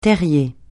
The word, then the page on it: terrier: francais